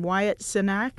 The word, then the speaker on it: Wyatt Cenac: We were talking earlier, and I'm always butcher his name, Wyatt Cenac.